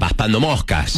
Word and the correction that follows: papando moscas: Se dice así, ¿no? – Paspando moscas. Sí, papando moscas.